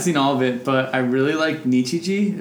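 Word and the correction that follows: Nichijou: Is that how you say it, Erwin? – Nichiju. Yeah.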